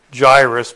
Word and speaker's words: Jairus: And behold, there came a man named and we pronounce it in English the Jairus, but it's in the Greek it's Iairos.